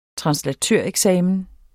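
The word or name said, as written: TRANSLATØREKSAMEN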